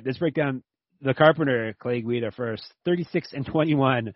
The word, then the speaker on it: Guida: Clay Guida.